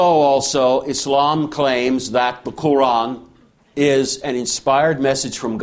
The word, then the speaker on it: Quran: Quran. You don't ask how you're supposed to pronounce that Q. It's not supposed to sound like a K. It's got to be done further back in your throat.